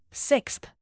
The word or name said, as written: sixth